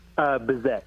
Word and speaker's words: Bizek: Bizek.